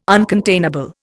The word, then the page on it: uncontainable: Indian